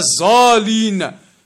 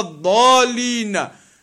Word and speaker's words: الضالين: You hear them saying, غير المغزوب عليهم ولا الظالين. That is not even the Arabic, the correct pronunciation, the ضاد. غير المغضوب عليهم ولا الضالين.